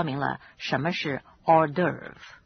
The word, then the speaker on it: hors d'oeuvre: 这种小点心有一个特殊的名称，那就是 hors d'oeuvre. It is spelled H-O-R-S and D apostrophe O-E-U-V-R-E. It's pronounced hors d'oeuvre.